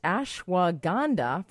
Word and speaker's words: ashwagandha: because I'm American and the H is silent, but it's ashwagandha, formerly known as Withania somnifera.